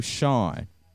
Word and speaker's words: Sean: I thought about changing my name once because so many people kept messing up my first name, Sean.